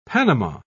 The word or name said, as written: panama